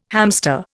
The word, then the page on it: hamster: Indian